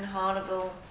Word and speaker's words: Harnival: the DDG, Mabichi Thompson, Malebo, is it, Incentive Development and Administration, and Mr. Stephen Harnival, Chief Economist. You've got a fantastic sense of humour, Stephen, which, and I can see the name Harnival rhymes with carnival.